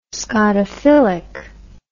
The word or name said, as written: scotophilic